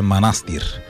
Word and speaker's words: манастир: Аз в четвъртък казах, че селото е манастир. Веднага Михаил Делирадев ме контрира с: абе, знаеш ли, че ударението е манастир? – Ами това е като винарска изба Драгомир.